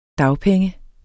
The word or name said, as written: dagpenge